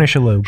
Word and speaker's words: Shalhoub: Tony Shalhoub.